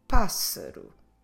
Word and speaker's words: pássaro: The word for bird is pássaro. Pássaro. I'm stressing the first syllable because there's an accent on the A, an acute accent to the right. So that makes us stress the first syllable. But after that A with an accent, we have a double S. So let's do a S sound. Pássaro.